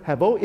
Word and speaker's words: hevel: Now, the Hebrew word is hevel, pronounced as hevel. It's spelled as H-E-B-E-L, but pronounced as hevel, as in the English word level.